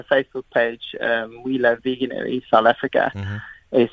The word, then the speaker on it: Veganuary: veganuary. – Yes. veganuary.